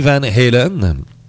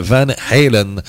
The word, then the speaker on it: Van Halen: Donc, très important aussi la prononciation Van Halen. C'est pas Van Halen. Alors, plusieurs vont faire cette erreur-là, ils vont dire Van Halen, mais c'est Van Halen, le H... il est comme bloqué, là, tu sais, c'est comme un Hongrois et non un Hongrois, là, tu sais.